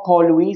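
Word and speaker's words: Porlwi: Yeah, it's more Port Louis, so it's P-O-R-L-W-I, and that's how they say it and write it in the local Creole language, which is kind of like an offshoot of French, but it's, the Port Louis Festival, if you want to say.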